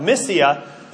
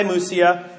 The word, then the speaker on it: Mysia: So they passed by Mysia. But by the way, in Greek, the Y was an U sound. Obviously, we pronounce it as an E sound. So it's Mysia if we're reading it in English, Mysia if we're reading it in Greek, but we're reading it in English.